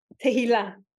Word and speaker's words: Tahila: That's as close as someone who doesn't speak Hebrew pronounces it. Tahila.